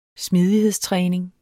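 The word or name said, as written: smidighedstræning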